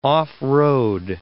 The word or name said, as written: off-road